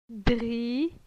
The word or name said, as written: Dri